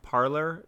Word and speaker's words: Parler: First off, I think the name itself, Parler, is kind of funny, because, despite being pronounced parlor by most people, it's actually supposed to be the French word parler, as in, you know, the French verb to speak.